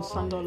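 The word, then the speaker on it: Szandor: Ja, ich müsste wissen, wie das da ausgesprochen wird. Ist das Shandor? – Ah, Anton Sandor Levey.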